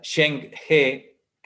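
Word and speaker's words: Zheng He: googleas a Sheng He, que, se escribe z H Eng, Sheng HE,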